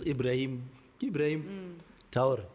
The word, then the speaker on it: Ibrahim Traoré: So, come on, even with this Ibrahim Traoré.